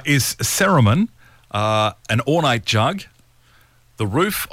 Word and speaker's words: cerumen: CERUMEN. – Not Saruman, like out of Lord of the Rings.